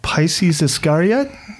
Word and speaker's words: Pisces Iscariot: I went with a song off of, I think I'm going to pronounce this properly, Pisces Iscariot? – Yeah, that's fine.